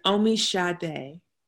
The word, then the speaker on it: Omisade: Okay, tell me how to pronounce it. – It's Omisade. – Omisade. Oh, like the singer.